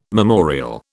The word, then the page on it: memorial: British English